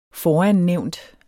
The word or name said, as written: forannævnt